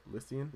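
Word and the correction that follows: Lúthien: Lathien. – Lathien. Sorry, guys.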